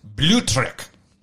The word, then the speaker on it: Blutrich: Boltrich. – I would like to believe that it's Blutrich. – Blutrich. – Just like really That's, German like that.